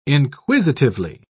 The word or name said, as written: inquisitively